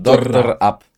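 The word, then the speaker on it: DR AP: Drap, tylko DR spacja AP. Tak jakby dok doktor, doktor Ap.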